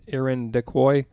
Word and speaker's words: Irondequoit: Though this is the most popular area for sightings of the White Lady, she has been spotted throughout the park and in Irondequoit area, including at the golf course and nearby neighborhoods and the in Irondequoit Cemetery. Yeah, it's spelled like iron to quote, but anyway, that's probably perfect right there.